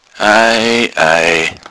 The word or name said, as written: eiei